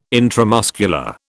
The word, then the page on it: intramuscular: British English